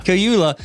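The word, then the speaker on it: Kaluuya: Cayula.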